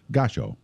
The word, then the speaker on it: Gashco: They've got a line called It's spelled G-A-S-H-C-O, but pronounced Gasho.